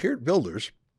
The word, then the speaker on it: Geert Wilders: And incredibly, Geert Wilders, it's spelled Geert Wilders, but it's pronounced Geert Wilders,